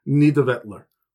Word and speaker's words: Nidavellir: Far below these continents, or above if you're from Muspelheim, it never stops being confusing, is Nidavellir, which it took me many, many YouTube videos to be able to pronounce it. It's not spelt like that, but it is Nidavellir, which means dark fields.